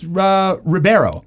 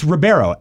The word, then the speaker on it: Ribeiro: No, it's Ribeiro. R-I-B-E-I-O. – What, his name can't be pronounced? What's the bad news? – Bad news is the dude has to say your name. – Is it Ribeiro? – It's Ribeiro. Alfonso Ribeiro.